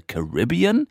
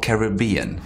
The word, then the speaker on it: Caribbean: It took me a while to say Caribbean, because I always say Caribbean, because in Spanish it's Caribe, so the accent is goes in ca, Caribe. Caribbean sounds like strange to me, but that's the way it's pronounced, right? – I think so.